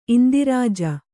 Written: ಇಂದಿರಾಜ

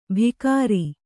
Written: ಭಿಕಾರಿ

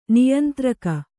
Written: ನಿಯಂತ್ರಕ

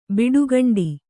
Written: ಬಿಡುಗಂಡಿ

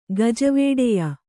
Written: ಗಜವೇಡೆಯ

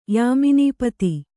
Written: ಯಾಮಿನೀಪತಿ

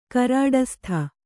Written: ಕರಾಡಸ್ಥ

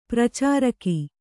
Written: ಪರಿಚಾರಕಿ

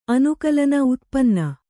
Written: ಉತ್ಪನ್ನ

